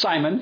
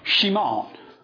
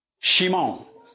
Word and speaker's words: Shimon: His name really should not be pronounced as Simon. It should be called Shimon. Shimon.